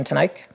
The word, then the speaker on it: Teneycke: Just my name, Jim Tenyck. – Jim Tenyck. And can you spell that? J-I-M? – T-E-N-E-Y-C-K-E.